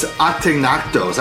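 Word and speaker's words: Ategnatos: I think it's Ateganatos. I think you don't say the G.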